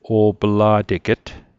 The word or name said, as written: o'pla'teget